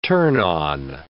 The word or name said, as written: turn-on